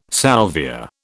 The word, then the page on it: salvia: British English